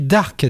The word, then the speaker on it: Darc: Alors son nom s'écrit Darc, D-A-R-C comme Mireille, même s'ils n'ont aucun lien familial. Mais en fait c'est un jeu de mots avec Dark qui signifie sombre en anglais, un peu comme Dark Vador.